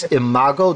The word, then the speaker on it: Imago: And the name again, you said, is Imago. Did I say that right? – Yes, I-M-A-G-O.